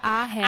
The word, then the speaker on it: Ahern: Cecilia Ahern.